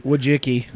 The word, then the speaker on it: Wojcicki: Wojcicki. – Wojcicki, yeah. I guess we'll Wojcicki, say. Wojcicki, because that's the YouTube owner's last name as well. – Okay, so Ann Wojcicki, she married Google co-founder Sergey Brin in 2007 and divorced 8 years later.